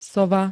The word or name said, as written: sova